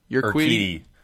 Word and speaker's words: Urquidy: Urquidy. – Urquidy.